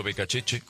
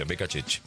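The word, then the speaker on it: Beccacece: Está hablando Becacheche, así se pronuncia, Becacheche.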